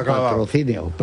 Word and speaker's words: Patrocinio: Eduardo lo ha pronunciado patrocinio. – Perfecto.